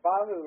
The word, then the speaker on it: Bahu: Bahu like Yahoo.